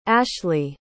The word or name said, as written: ashleigh